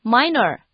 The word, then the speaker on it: minor: Minor, M-I-N-O-R, 表示轻微的。不要觉得它看起来和镜子 mirror 长得很像，就把 minor 念成 minor, 这可是会大扣分的哦。